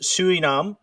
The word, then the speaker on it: Sonam: Sonam, pronounced Suinam, means with merit, and Wangdu means spiritual power.